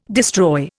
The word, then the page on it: destroy: Indian